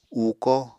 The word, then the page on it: uuko: Apatani